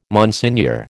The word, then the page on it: Monsignor: American English